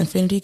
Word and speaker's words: Infinitie: It is spelled I-N-F-I-N-I-T-I-E. Please forget, don't forget the I-E. It is not Y. Jesus. – And beyond. – Folks will spell my name I-N-F-I-N-I-T-Y so quick.